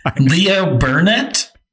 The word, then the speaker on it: Leo Burnett: And then Leo Burnett, the ad agency. – How did you pronounce that? – Say it for me. – Leo Burnett? – What is it? – Leo Burnett. – Leo Burnett.